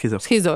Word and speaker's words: schizo: Schizo? – Schizo, jo, jako od schizofrenie.